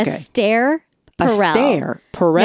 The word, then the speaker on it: Esther Perel: Now, it's spelled Esther, but everyone calls her Esther. She's Jewish, and that's the Hebrew way of saying the word Esther. So she is known as Esther Perel.